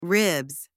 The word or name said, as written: ribs